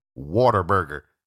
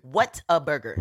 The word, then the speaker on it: Whataburger: I said, why are you saying water burger? She said, that's what it's called. No. What is it? You gotta say it. – Whataburger. – Whataburger. – That's how it's spelled.